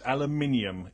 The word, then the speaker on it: Aluminium: Aluminium is the correct pronunciation. – You know, if that's... I'm going to bet you it's not. – Okay. Your tweet box is going to be very full. You're going to get a good filling. – Aluminium. – Anyway. – The emphasis is on that syllable, Madan.